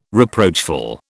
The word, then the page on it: reproachful: British English